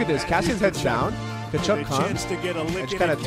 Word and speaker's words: Tkachuk: Zach Cassian responds after learning that NHL deemed Matt Tkachuk's hits Tkachuk. – Legal. Tkachuk's hits legal by saying, "Good, now I can do what he does." – The T is silent.